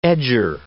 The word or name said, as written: edger